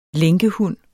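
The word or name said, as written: lænkehund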